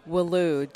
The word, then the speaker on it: Welu: And so years go by, and you know, when I was about 21, I applied at Walu Dental Lab in Fargo, North Dakota. – How do you say it? – Welu. W-E-L-U.